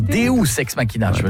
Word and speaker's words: Deus ex machina: Deux ex machina. Non, Deus ex machina. – Ah, parce que deux, j'aurais dit que c'était deux ex que t'appelles machine. Tu vois. – Non, ça se prononce Deus ex machina.